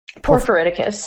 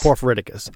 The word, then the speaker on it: porphyriticus: And Porphyriticus. – Porphyriticus, okay. Yep. So Porphyriticus, what constitutes the ideal stream habitat for this species? – Yeah, so Gernophilus porphyriticus, or just northern spring salamanders, is fine too.